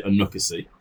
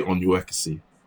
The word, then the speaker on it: Onuekwusi: So I pronounce it Onuekwusi. – Onuekwusi. – Yeah. That's what my dad pronounced it. But I think if you took it the Igbo way, it'd be Onuekwusi.